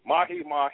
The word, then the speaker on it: mahi-mahi: And also had a little salmon and some, I always say it wrong, I always call it Maui, was it Mahi-Mahi? Mahi-Mahi. – Yeah, Mahi-Mahi, yeah. Nice.